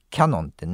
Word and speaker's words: キヤノン: キャノンってね、小さい矢で発音しますけどもね、キヤノンですからね、正しい表記はね、キヤノンは、 5月30日国内で扱っている唯一のフィルムカメラ、フィルムカメラである EOS 1V、うん、どっちだろう、 EOS 1V の発売を終了すると発表しました。